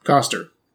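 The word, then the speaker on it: Koster: No, it's like Cotter. Coster? Oh. Coster. Wow, that's embarrassing. Coster!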